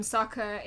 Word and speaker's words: Sokka: I liked that one of the lines was, I'm Sokka, it's pronounced with an orca, because it reminded me of the fact that they call him Sokka in the Avatar live action film.